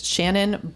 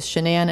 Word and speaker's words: Shanann: So the first thing I'll say is I am pronouncing her name correctly. If you see it in writing, you might glance quick and think it is Shannon, but it is Shanann.